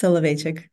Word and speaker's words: Soloveychik: It's Soloveychik.